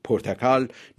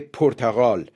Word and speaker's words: پرتغال: شاید به سبب دشواری تلفظ پرتکال، نام آن کشور رفته رفته در فارسی به پرتغال تغییر یافته است.